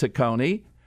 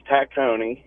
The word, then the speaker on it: Tacony: All right, let's go to Tacony, or sometimes called Tacony. Pete is with us. Hello, Pete. Welcome to the show. – Hi, Dom. How are you? – Good. – And it is pronounced Tacony.